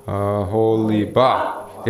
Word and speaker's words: Oholibah: Aholibah. – In the King James, it's weird. That's Oholibah. Yeah, Oholibah, that's Hebrew. All right, go ahead. Yeah, the accent's on the last syllable. So, Oholibah. Oholibah.